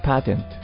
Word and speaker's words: patent: ナレーターさんは、 patent と発音していましたが、これは、 patent でも OK ですね。パテントです。